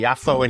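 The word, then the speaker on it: Yaffo (יפו): And he's just been a great source of knowledge about this wonderful diaspora food, which we're enjoying here in Yaffa, or Yaffo, depending on how you pronounce it, I guess. – Yaffo. – Yaffo. – Yaffo. – Okay, Yaffo in Hebrew. – Yaffa in Arabic. And Jaffa in English.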